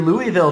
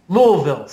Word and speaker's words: Louisville: Well, and like that guy who, when you did your Louisville trip this year, it's Louisville, right? – Louisville, sorry. – Louisville. – I mispronounced it.